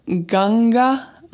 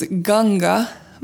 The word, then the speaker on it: Ganga: And today's mantra is Jai Ganga Ma, and Ganga is spelled G-A-N-G-A.